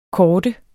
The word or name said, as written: korde